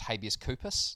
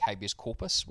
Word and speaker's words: habeas corpus: That username will be pronounced habeas corpus, like habeas corpus, which is a...